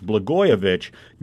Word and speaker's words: Blagojevich: I mispronounced that Blagojevich. It's Blagojevich.